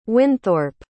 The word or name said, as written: winthorp